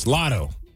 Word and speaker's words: Latto: Yeah, you say her name right. It's Latto.